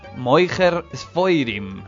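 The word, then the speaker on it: Mocher Sforim: Moicher Sfoirim significa literalmente vendedor de libros, del hebreo Moher Sfarim, en pronunciación yiddish.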